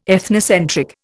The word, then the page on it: ethnocentric: Indian